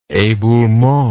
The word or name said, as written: eboulement